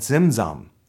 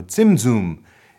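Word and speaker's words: tzimtzum: There's ancient commentators and Jewish scholars around the Middle Ages came up with this term called zimzum, or zimzum, if you want to have the correct pronunciation, meaning that God actually pulled back a little bit to create space for the other.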